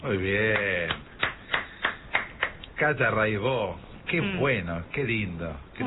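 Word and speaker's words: Raybaut: Buenísimo, Cata Raibó. Se escribe Raibaus, métanse, búsquenla, Rai con Y, Baut con B larga. Catalina Raibaut, Raibó se dice, y bueno, cotidiano son 10 canciones, hay un documentalito muy lindo.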